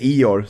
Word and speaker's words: Igor: Igor Surakovsky. Eller Ior som han själv eh, heter och uttalar mer.